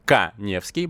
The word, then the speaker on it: Каневский: Подпишитесь на мой инстаграм, Эдуард Каневский. Моя фамилия пишется через А. Конем меня, пожалуйста, не склоняйте.